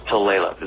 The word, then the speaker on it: Tulalip: So Tulalip is the way it's pronounced. – Tulalip.